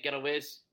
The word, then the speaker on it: Gilloise: Eintracht Frankfurt 2, Union Saint Gilloise. I butchered that pronunciation. – Gilloise.